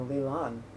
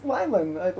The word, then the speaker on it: Lylan: I noticed in the show they were calling her Lilan. I was like, but her name's Lylan, right? Pretty sure it's pronounced Lylan.